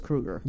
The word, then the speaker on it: Kruger: Is it Kruger? It is Kruger. – Yeah, K-R-U-G-E-R. – Yeah, just like Kruger.